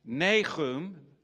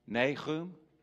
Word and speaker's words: Nahum: Nahum, the prophet Nahum.